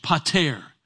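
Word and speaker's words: pater (πατήρ): Now the word Father there is a Greek word that is pronounced pater. It's where we get the English word paternity from.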